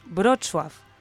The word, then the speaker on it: Wrocław: Para empezar, la ciudad de Wrocław, no es Wrocław, es Wrocław. O sea, tienes que deducir cómo se pronuncian, porque la W es una F, la L es una U, bueno, es una locura.